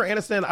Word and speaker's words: Aniston: Oh, it sounded like you said Anderson. – that would be you. Tre'vell Anderson. – Um, no, Jennifer Aniston. I was I was irritated with Jennifer Aniston in this moment because like her response to it is what made it such a big thing, right?